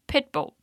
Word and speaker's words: pitbull: Enquanto no Brasil a gente fala pitbull, em inglês dizemos pitbull.